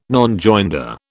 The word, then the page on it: Nonjoinder: British English